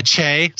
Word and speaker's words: Che: just because Jost and Shay and, and uh, Che, sorry, I, we get in trouble for saying it wrong, but Che are so strong.